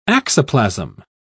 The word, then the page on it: axoplasm: British English